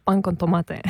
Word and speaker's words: pan con tomate: How do you say it? – Pan con tomate. – Yeah, there we go.